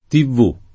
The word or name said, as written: tivvù